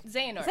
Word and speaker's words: Xehanort: It's Xehanort. – Yeah, you said it right. Xehanort.